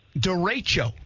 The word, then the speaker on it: derecho: Derecho.